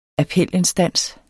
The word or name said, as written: appelinstans